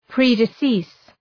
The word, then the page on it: predecease: ,pri:dı’sıs